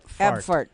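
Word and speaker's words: Abfahrt: Abfart. That's what it's called. That's how it's pronounced.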